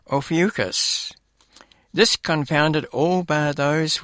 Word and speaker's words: Ophiuchus: How do you go about pronouncing it? Ophiuchus. Ophiuchus.